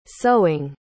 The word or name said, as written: sowing